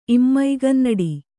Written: ಇಮ್ಮೈಗನ್ನಡಿ

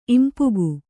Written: ಇಂಪುಗು

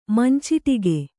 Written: ಮಂಚಟಿಕೆ

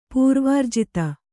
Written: ಪರಿವರ್ಜಿತ

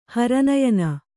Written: ಹರನಯನ

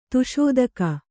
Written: ದಶದಿಕ್ಕು

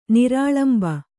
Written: ನಿರಾಳಂಬ

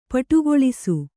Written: ಪುಟಗೊಳಿಸು

